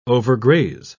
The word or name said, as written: overgraze